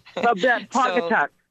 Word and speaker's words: Pawcatuck: So we need for Quill to hang up so he can take on Sharon, who is from, I don't know how to pronounce that, Pawcatuck. – Pawcatuck. – Okay, there we go.